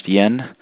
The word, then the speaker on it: Jan: It's J-A-N, pronounced Yen.